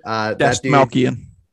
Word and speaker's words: Dastmalchian: One guy I want to, I call it, I don't know how to pronounce his last name, David Dastmalchian or whatever. – Dastmalchian. Yeah, sure.